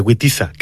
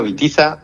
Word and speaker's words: Witiza: Sí, bueno, Witiza, que al ser godo se pronuncia como una B, aunque todos lo pronunciamos como una V,